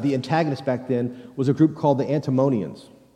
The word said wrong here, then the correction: Antinomians: Antinomians is how you pronounce it, right?